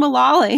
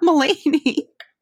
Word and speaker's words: Mullally: No, Megan Mullally. It's not Mulaney. – Okay, either John Mulaney or Megan Mullally.